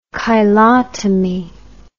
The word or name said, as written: cheilotomy